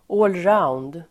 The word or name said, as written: allround